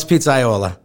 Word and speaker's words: pizzaiolo: But you are the only pizza Iolio? How bad did I say that? – Well, it's Pizzaiola. – Pizzaiola. So, I totally screwed that up.